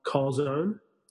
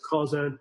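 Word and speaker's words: chazon: And I used Craig Groeschel's book called Call Zone. It's spelled chazone, like the Hebrew word for vision, I believe. – Okay. – It's call zone.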